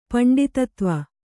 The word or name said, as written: ಪಂಡಿತತ್ವ